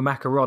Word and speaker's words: macaron: I got schooled one day in Paris for asking for a macaron, and they said, no, it's macaron.